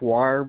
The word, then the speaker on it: Weyer: Pronounced wire, but it's spelled WEYER.